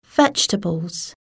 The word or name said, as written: vegetables